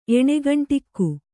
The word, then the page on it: ಎಣ್ಗಂಡುಗ: eṇgaṇḍuga